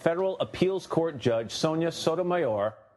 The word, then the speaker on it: Sotomayor: The correct pronunciation is Sotomayor, unless you're a Republican, and then I believe it's pronounced Sodomizer. So now we have the pronunciation.